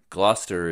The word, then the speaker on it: Gloucester: Gloucester is the correct pronunciation, which we were 0-for-6, I think, on trying in our last episode. But so Gloucester, which is the spelling of it doesn't imply that.